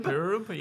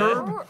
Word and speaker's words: Hryb: Uh, Larry Hryb. Hryb. – Yeah. Hryb.